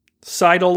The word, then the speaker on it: Seidel: It's Molly Seidel. Okay, Seidel, like the verb.